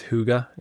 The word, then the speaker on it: hygge: It's, what's that Danish term? I think it's hygge. – Hygge is how you pronounce it.